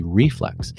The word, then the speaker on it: reflex: Your natural reflex, why did I put the emphasis on the wrong syllable? It's really reflex.